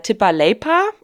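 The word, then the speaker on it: tippaleipä: Das typisch finnische Gebäck Tippa Leipä. – Hast du aber gut ausgesprochen. – Tippa Leipä? – Also ich...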